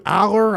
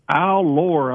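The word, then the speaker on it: Allar: Like, look at the pronunciation on the Steeler website. It's Allure, I believe. So Drew Allure is actually the correct way, it looks like, from the way they have it on their pronunciation guide.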